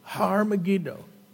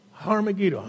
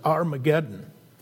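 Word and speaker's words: Har Megiddo (הר מגידו): The word for mountain and just mountain is Har in Hebrew. Har Megiddo. Har Megiddo. Har Megiddo. And people mistranslate that, mispronounce it as Armageddon.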